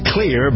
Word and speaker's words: Xlear: It's pronounced clear, but it's spelled X-L-E-A-R.